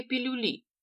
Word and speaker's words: пилюли: Если на морском спруте их ждут «трындец какие пилюли», цитата Барби, в пилюлях ударение на последний слог, они кинут клич парням Сорха.